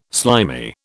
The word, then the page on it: slimy: British English